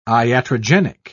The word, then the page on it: iatrogenic: British English